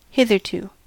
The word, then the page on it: hitherto: American English